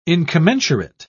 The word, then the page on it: incommensurate: British English